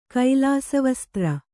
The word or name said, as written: ಕೈಲಾಸವಸ್ತ್ರ